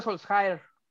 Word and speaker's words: Solskjaer: este Oleguner Soljacker. Oleg, Oleg Solskjaer.